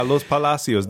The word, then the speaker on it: Los Palacios: I remember one dark night we were going to a town, and I'm going to try and pronounce it correctly, Los Palacios? – Yeah, Los Palacios, the palaces.